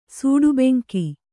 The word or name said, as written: ಸೂಡುಬೆಂಕಿ